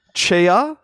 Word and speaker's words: Tchia: So I've been playing Tchia.